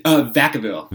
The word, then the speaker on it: Vacaville: So you live in, how do you pronounce it? Vacaville, California? – Vacaville. – Vacaville. – Yeah, Vacaville. Thank you.